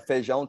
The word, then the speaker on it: feijão: But feijoada tropeiro, or is that how you say it? Sorry, my gringo accent's coming out. – No, feijão tropeiro.